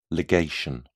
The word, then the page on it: ligation: British English